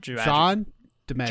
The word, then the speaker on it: John DiMaggio: John DiMaggio.